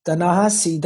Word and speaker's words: Ta-Nehisi: That's the opposite, because Ta-Nehisi, that's how you pronounce him? – Yeah.